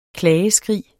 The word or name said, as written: klageskrig